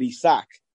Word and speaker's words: Isaac: My name is Isaac. In Espanol, you pronounce it Isaac.